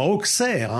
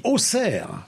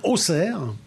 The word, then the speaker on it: Auxerre: Alors, petite information, j'étais en déplacement du côté de Auxerre, parce qu'on ne dit pas Auxerre, hein, on dit Auxerre. Je me suis fait reprendre en arrivant là-bas, donc je le dis bien, Auxerre.